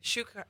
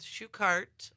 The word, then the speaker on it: Shuchart: Shuchart. Well, oh, did I not? – Some people in the family even say it differently. It's Shuchart, like a shoe cart.